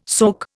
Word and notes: soak: Indian

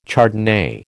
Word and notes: Chardonnay: American English

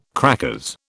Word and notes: crackers: British English

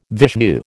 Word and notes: Vishnu: American English

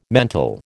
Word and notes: mental: American English

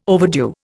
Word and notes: overdue: Indian

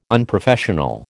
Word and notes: unprofessional: American English

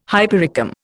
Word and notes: hypericum: Indian

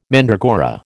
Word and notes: mandragora: American English

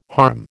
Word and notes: harm: American English

